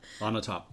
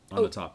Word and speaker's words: Onatopp: Onatopp. On top. Onatopp, yeah. It's a play on words. She's on top, you know? – Yeah. – Xenia Onatopp, I think it is.